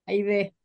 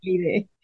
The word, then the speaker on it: Haydee: We had quite the intro, as I was bound and determined to pronounce your name the way it's originally supposed to be pronounced and not the Americanized version. And it's Aide. – Aide. Yes. Aide.